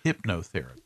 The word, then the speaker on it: hypnotherapist: A leading hypotherapist gives tip to stop the emotional eating because, look, diets and exercise are important factors in what we do to gain weight. But it's pronounced hypnotherapist.